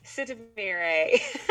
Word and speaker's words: Sitamire: On your last name, help me out. – Sitamire. – Sitamire, thank you. I have been saying her last name wrong in all of my promotions, and I'm trying to say it right. It's got a little flair and finesse. – It does. Yes, it does. Sitamire. – Yeah. Yes. So that is why, because it's different than it is spelled, or it's different than it looks.